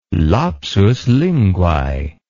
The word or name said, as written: lapsus linguae